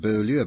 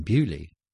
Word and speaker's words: Beaulieu: is pronounced Bewley and not Beaulieu, because it's actually spelt Beaulieu? – Oh, you see, I never thought about it.